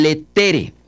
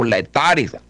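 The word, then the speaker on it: Laetare: El tercer domingo de Adviento de Gaudete y el de Cuaresma, que se llama el Letere o Letare, bueno, así la pronunciación.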